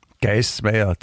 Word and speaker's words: Guysmayor: Geißenpeter, der Geismeier, klingt, Geißenpeter klingt nach dem Typen von der Heide, aber Geismeier? – Peter wer? Geismeier. Das ist Geis und schreibt man G-U-Y-S eigentlich, also die, der Männermeier, also der, der Mann der Männer, Meier.